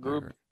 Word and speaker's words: Grube: Grube.